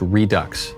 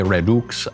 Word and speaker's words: Redux: And that's how you pronounce it, by the way, is Redux. No, I was pronouncing it in that's the right. French way, maybe the Latin way, Redux.